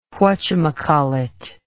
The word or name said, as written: whachamacallit